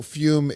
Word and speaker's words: Fum: Fume is pronounced Fume, but it's spelled F-U-M, Fum, if you guys are a phonetic assholes.